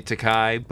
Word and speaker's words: Takei: George, is it Takei or Takei? I don't even know. – I would say Takei, but I will never tell you pronounce anything wrong.